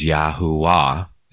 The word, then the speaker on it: Yahuwah: The best that I can do is to pronounce his name phonetically as Yahuwah.